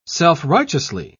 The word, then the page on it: self-righteously: British English